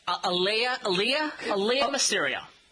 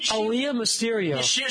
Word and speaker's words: Aaliyah Mysterio: Aaliyah Mysterio. She was in Aaliyah Mysterio.